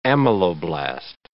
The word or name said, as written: ameloblast